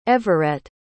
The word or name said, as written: everette